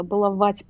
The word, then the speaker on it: баловать: Я, кстати, только что поняла, что я неправильное ударение сделала. Баловать, простите меня, а.